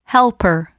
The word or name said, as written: helper